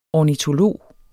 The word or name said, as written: ornitolog